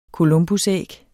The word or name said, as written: columbusæg